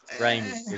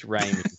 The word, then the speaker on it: Raimi: Raimi is how you pronounce it. Yeah. – Rami. – Just in case we ever interview him, it's Raimi.